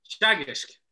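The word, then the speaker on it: Teagasc: Like, I watch a lot of the it's spelt as Tegasek. That's what I say. How do you pronounce it? Sorry, Chagish.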